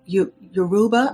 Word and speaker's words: Yoruba: Yoruba, I hope I'm pronouncing yes, that's correct.